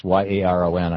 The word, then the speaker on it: Yaron: Was it Yeren from Ayn Rand? – Yeah, I think the emphasis is on the second syllable. It's Yaron. I think it's Yaron.